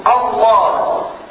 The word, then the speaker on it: الله: إذا هذه همزة وصل ليست همزة قطع، لكن حينما أبتدئ اسم الجلالة كيف أقرأه؟ أقول الله الله هكذا الله، فأنطق بهمزة الوصل همزة قطع تلك الهمزة التي مخرجها هي نفسها التي مخرجها من أقصى الحرف حيث مخرج الهاء.